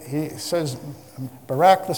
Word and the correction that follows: Barak: son of, Barak, rather, the son of Abinoam, accident on the first syllable,